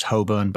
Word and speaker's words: Holborn: Also, I think it's pronounced Holborn, but carry on. Set the scene. – We're in Red Lion Square, Holborn.